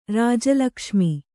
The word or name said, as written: ರಾಜಲಕ್ಷ್ಮಿ